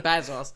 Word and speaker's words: Bezos: I did mispronounce his name the first time. – I'm just like Bezos. – Bezos.